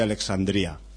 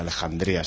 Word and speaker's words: Alejandría: el sueño de Alexandría, o de Alejandría, si lo pronunciamos ahí como debe ser, ¿no?